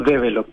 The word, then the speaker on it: develop: eres socio y director de no sé si lo pronuncio como develop, como en inglés, pero se escribe develop, digamos. Eh, ¿Sí? ¿Está, correcto? Sí, la verdad es que le decimos develop. Ya, develop, entonces. Como bien chilenizado.